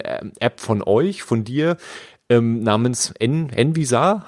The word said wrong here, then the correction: Revisa: habe ich das richtig ausgesprochen? Revisa. Revisa, so rum.